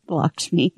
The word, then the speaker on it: Lakshmi: All right, so celebrate Lakshmi. I can't ever say the name right. That's the Lakshmi. Yes.